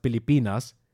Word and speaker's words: Pilipinas: Philippinen in der Landessprache heißt Pilipinas. Also jeder F-Laut mit, oder jeder F-Laut wird in der Regel dort mit P ausgesprochen. Also Pilipinas.